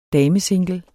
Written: damesingle

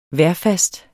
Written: vejrfast

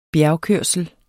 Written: bjergkørsel

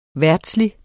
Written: VERDSLIGE

